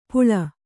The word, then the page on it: ಪುಳ: kannada